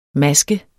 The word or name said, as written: MASKENS